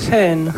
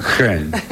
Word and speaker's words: Hohn: Sí, H-O-H-N. – H-O-H-N. ¿Y cómo se dice? – Hen. – Hen. Es alemán.